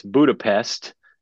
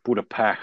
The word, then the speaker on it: Budapest: And it's Budapest, as it's written on paper, but there's people that pronounce it Budapest, like Joe Pesci, Budapest.